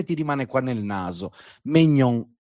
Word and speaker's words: Maignan: Allora, la N finale non la devi neanche leggere. La N finale non la devi neanche pronunciare. Okay. Se ci fosse una E alla fine, pronunceresti anche la N finale. La E alla fine non c'è, quindi quella lettera lì te non la devi pronunciare. Però la AI si dice E, Perché me, cioè ma, me, è scritto mais, mignon. Quindi me, quindi è mignon.